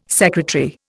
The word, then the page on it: sec: Indian